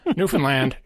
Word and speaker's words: Newfoundland: There you have Newfoundland. It rhymes with understand, in case you ever want to know how to pronounce it.